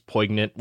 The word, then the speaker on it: poignant: I think it's a soft G, poignant? A silent G kind of sort of situation.